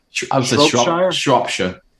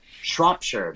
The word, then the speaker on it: Shropshire: The Shropshire, did I pronounce that correctly? – Shropshire. – Shropshire, very good.